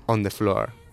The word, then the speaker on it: On the Floor: Os dejamos con Jennifer López y Pitbull con On the Floor. Lo he dicho bien, ¿no? – On the Floor.